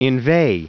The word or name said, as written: inveigh